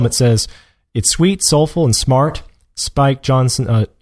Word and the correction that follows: Jonze: you pronounce that Johnson? Spike Jonze, uses its just barely sci-fi scenario to impart wryly funny wisdom about the state of modern human relationships.